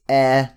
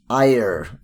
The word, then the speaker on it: Eyre: Um, is actually pronounced air, not ire, as I said.